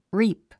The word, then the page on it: reap: ríːp; リープ